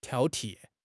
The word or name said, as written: 挑剔